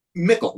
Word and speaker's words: Michael: Mickle, like pickle with an M.